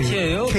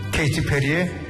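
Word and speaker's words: Katy Perry: 뭐라고 읽어요? 저 영어? – KT 페리. – 카티예요? – KT 페리입니다. KT 페리의 캘리포니아 걸스를 주시면서 그 일구 아저씨가 그 구박받는 중앙 어르신께 들려드릴 노래라면서 KT 페리의 곡을 주셨는데 아, 이거 선생님한테 헌정하는 곡이래요.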